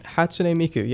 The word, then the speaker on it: Hatsune Miku: Do you even want to say his name for me? Hatsune Miku. Miku. – Hatsune Miku. Yeah, that yes. – was really close. – That was closer than most.